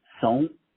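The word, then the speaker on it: São: And S-A-O is son.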